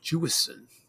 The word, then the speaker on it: Jewison: Jewison.